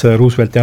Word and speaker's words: Roosevelt: President Roosevelti teemadel, siis ta vaatas Roosevelti hälduse peale väga juhmine aga otsa ja siis küsis, et do you mean Roosevelt? – Roosevelt.